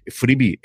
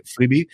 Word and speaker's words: Freevee: Freevee es pronunciado, es exactamente igual que como que es algo gratuito. Cuando te dan una cosa gratis en Estados Unidos o en inglés, se dice Freevee.